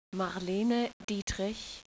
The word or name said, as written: Marlene